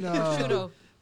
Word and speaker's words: prosciutto: You mean prosciutto.